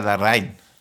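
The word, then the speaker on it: Larraín: Ja, ich weiß nicht, wie spricht man das richtig aus? Pablo Larrain? – Ja, also meine Freundin hat versucht, es mir aus äh, zu erklären, es ist ja Larrain. – Larein.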